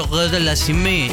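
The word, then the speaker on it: Auger-Aliassime: Pasa que como Tomás es nativo francés, me dice, se pronuncia Auger de la Cime.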